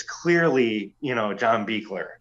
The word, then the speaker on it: Buechler: How do you say it? – Buechler. – Buechler. – John Carl Buechler. Yeah. – That's right.